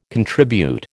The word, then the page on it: contribute: American English